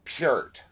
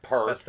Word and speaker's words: Peart: And it's pronounced Peart, not Peart. – That's the Canadian Peart. That's the Canadian pronunciation there. – Yes, that's his correct pronunciation.